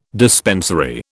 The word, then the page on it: dispensary: British English